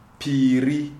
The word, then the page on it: Piri: Shona